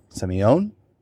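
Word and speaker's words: Sémillon: and it is called Semillon. – Semillon? – Semillon. – Yes. – And we are pouring a Semillon, a 2018 Semillon, from Amavi. – Yes. – I said it right.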